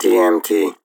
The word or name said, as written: DMT